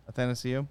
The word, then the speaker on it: Athanasiou: The third goal that Anathasiou scored, did I say that right? – Athanasiou.